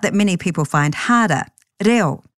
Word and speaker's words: reo: So if you know that Māori vowel sounds are a, e, I, or u, bring that knowledge to the word, reo, reo. Splitting any Māori word into syllables that end with a vowel will help you get your head around how to tackle the word, reo.